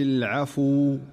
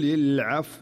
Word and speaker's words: العفو: فالخطأ هو قل العفو أو العفو، وهذه كلها أخطاء. والصحيح إذا أراد أن ينطق هذه الكلمة عند الوقف عليها أن يقول قل العفو قل العفو، يتحكم في حركة الفاء ولا يهمل الواو فيقرأها بهذه الطريقة قول العفو.